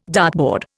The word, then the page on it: dartboard: Indian